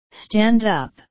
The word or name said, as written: stand up